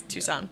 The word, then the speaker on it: Tucson: Which is spelled like Tuscan, but it's Tucson. Pronounced Tucson, that's the joke.